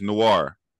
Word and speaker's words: Noir: How you pronounce that, Nori? Not Nori, because it's not Nori. It's Noir.